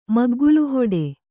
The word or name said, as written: ಮಗ್ಗುಲುಹೊಡೆ